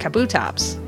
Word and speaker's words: Kabutops: I mean, I'm not sure I'm the authority on this, but I would say it Kabutops. – Kabutops. – But now I kind of want to say it Kabutops.